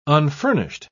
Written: unfurnished

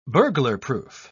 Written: burglarproof

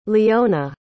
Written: leona

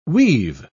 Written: we've

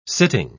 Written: sitting

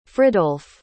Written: fridolph

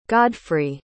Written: godfrey

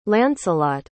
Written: lancelot

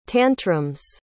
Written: tantrums

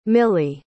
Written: millie

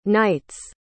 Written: night's

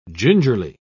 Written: gingerly

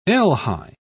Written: elhi

